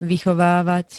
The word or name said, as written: vychovávať